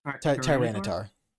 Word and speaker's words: Tyranitar: Tyranitar. – Tyranitar. If it rampages, it knocks down mountains and buries rivers. Maps must be redrawn afterward. – That's metal. That is so cool. Tyranitar is like basically the apocalypse.